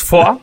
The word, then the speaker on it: Foix: Bueno, se escribe Foix. – Foix. – Según mi francés, culero, es Foix, pero aquí, al momento de tomar la Baur, güey, que después se toma por asedio, adivina cuántos cátaros son incendiados, güey.